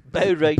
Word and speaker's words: Bo Rai Cho: Bo Rai Cho. – Bo Rai Cho. – Bo space Rai space Cho. Pronounced like that.